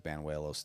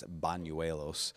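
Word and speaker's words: Bañuelos: Banuelos. I got that pronunciation, by the way, from the Yankees PR people. I thought it was Banuelos too, but it's Banuelos.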